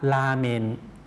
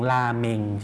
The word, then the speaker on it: ราเมน: ราเมนครับราเมนครับคนญี่ปุ่นคนไทยเราออกเสียงราเมง